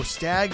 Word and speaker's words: Stagg: If you don't know, Stag 49 is S-T-A-G-G 49. – Second G is silent.